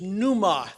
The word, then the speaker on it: pneuma: The Greek word for spirit is pneuma. There's a silent P at the beginning of that.